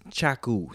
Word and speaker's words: Chaku: I go by the name of Chaku. – Michael Stokes, baby. What's good? – And it's Chaku, C-H-A-K-U. Every time I say my name, they're like, oh, Taco, right? – Taco.